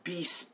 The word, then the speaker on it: Beast: He also does Beast. It's like bass, but it's spelled like beast.